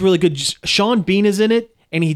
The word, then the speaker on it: Sean Bean: I believe it's pronounced Sean Bean. – Sean Bean. My mistake.